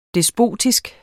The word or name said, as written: despotisk